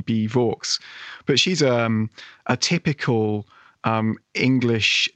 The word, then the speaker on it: Vaux: Well, the real life Anne Vaux, actually, we think she was called Anne Vaux. rhyming with forks.